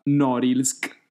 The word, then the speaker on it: Norilsk: Allora, vi parlo di questa cittadina si- nel nord della Siberia chiamata Norilsk, se la mia pronuncia eh, del russo è giusta. No, no, è giustissima.